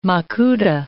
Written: makuta

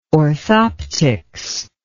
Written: orthoptics

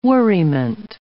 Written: worriment

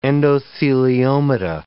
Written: endotheliomata

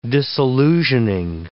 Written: disillusioning

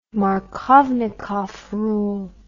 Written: markovnikov rule

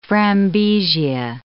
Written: frambesia

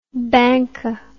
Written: banca

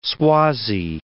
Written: Swazi

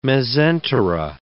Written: mesentera

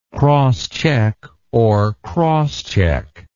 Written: crosschecker

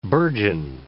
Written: burgeon

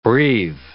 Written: breve

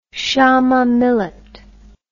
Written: shama millet

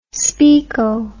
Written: speako

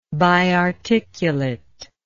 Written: biarticulate

